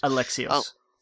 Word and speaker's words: Alexios: Cassandra and Alexis or Alexos? – Alexios. – Alexios, October 5th.